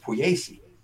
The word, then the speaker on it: Pugliese: I went to school with a girl who spelled her last name Puglies, which should be pronounced Pugliese. Mm-hmm. Well, in my hometown, it's Pugliese. That's how she pronounced it.